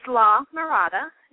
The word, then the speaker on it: La Mirada: Well, it's La Mirada.